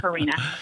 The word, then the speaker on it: Carina: Yeah, Carina. Well, either way, it's fine. Carina or Carina.